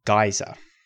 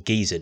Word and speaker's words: geyser: The final type of volcanic landform that I want to talk about here is a geyser, or geyser, depending on how you pronounce this thing.